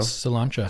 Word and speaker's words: Sláinte: So sláinte. – Sláinte. – Yeah. Sláinte. – Sláinte. – I like it. – Sláinte. – Yeah, it's spelt really awkwardly, not how it's said, like all Gaelic words. S-L-A-I-N-T-E. Sláinte.